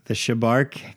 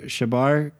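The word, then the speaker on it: Chebar: The CH would probably be a hard C sound. – Kabar? – Let's do it again.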